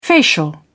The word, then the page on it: facial: British English